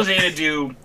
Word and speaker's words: Xanadu: Tokyo and then X. No, yeah, X A N A D U. – Oh my god. Bro, that's Xanadu. Yes, Tokyo Xanadu is a Falcom RPG.